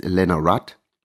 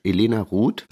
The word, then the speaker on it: Elena Ruth: Ich weiß diesmal auch, wie die Künstlerin wirklich ausgesprochen wird, weil ich nochmal nachgefragt habe. Ich dachte nämlich, sie heißt Elena Rudd. Falsch, Elena Ruth.